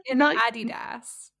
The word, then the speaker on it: Adidas: I love how you say both of those brands, because that is not how an American would pronounce either of them. – What did I say? – Nike Adidas. – Oh, right. So how do you... I wasn't aware there was a second pronunciation of Adidas. – In America, we say Nike and Adidas.